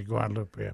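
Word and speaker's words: Guadeloupe: I think they actually pronounce it Guadalupe. – Yeah, we pronounce it Guadalupe.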